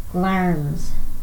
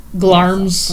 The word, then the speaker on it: Glamis: And we're going to Glamis Castle, which is actually pronounced Glarms. – Glarms.